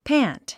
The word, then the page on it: pant: pǽnt; パァント